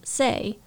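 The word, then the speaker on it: sei (星): All of the other planet names end in the character for star, which in compound words is usually pronounced sei.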